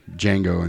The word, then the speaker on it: Django: And then D, of course, was from Django Unchained. – Right. – The D is silent.